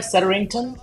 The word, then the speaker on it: Setterington: Let's just got the award, Mark Setterington. Am I saying that right, Setterington? – You are, very good.